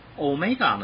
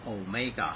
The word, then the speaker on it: omega: ，omega 呢，它是读妹妹的妹啊，omega，很多人会读成 omega 啊，那是电视广告手表。 Omega 手表，那是英文，英文读 Omega 哈，